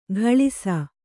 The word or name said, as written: ಘಳಿಸ